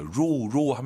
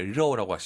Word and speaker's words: 肉: 로우 하면 안 돼요, 여러분. – 肉。 – 왜 러우라고 하시죠? 오유 발음은 어우니까.